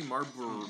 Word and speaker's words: Marlboro: How do you say Marlboro? – Marlboro. – Marlboro? – Marlboro. – Why do I think... Is there an L in there? – M-A-R... Marlboro. – Marlboro. Marlboro. M-A-R-L-B-O-R-O. – Marlboro.